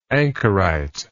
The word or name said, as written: anchoritism